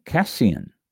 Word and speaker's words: casein: Is am I pronouncing that right? It's got a little thing. Casin. Oh, well, I wasn't pronouncing it right. Cassian, C-A-S-E-I-N, Cassian.